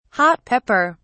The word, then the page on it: hot pepper: British English